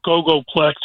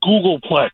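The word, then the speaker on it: googolplex: Evan, while you're waxing poetic on your mathematic knowledge, it's not Gogoplex, it's Googleplex. Jesus. – It's G-O-O-G-O. L-plex. Isn't that Gogoplex? – It's pronounced Googleplex.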